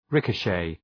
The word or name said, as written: ricochet